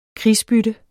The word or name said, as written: krigsbytte